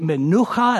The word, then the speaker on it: menucha: Now, that word is actually menucha. That's how you say it in Hebrew.